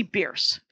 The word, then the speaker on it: Bearce: Her name is Chelsea Bierce, is what you should know. And Chelsea is spelt like Chelsea, C-H-E-L-S-E-A. And Bierce is spelt like Bear, C-E. So B-E-A-R-C-E.